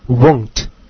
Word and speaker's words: won't: 它的缩写形式的发音呢是 won't， won't。